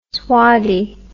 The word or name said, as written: swaddy